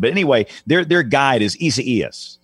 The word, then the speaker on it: Isaias: Isaias. – Is that close? – Is that correct? – That's it. – Isaias.